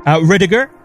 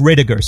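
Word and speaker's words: Rydyger: Riediger. So that's another interesting story because it's German.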